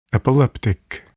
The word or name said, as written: epileptic